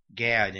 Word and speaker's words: Gad: Then the Eternal spoke to Gad, David's seer, saying, so now we have a prophet, Gad in English, or, you know, God in the Hebrew, but it pronounced Gad in English.